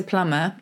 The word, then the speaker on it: plumber: They sneaked a B into plumber, but we didn't pronounce it because sound and spelling had long ago divorced.